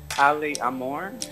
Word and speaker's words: Ale Amor: So you pronounce it Ale Amor.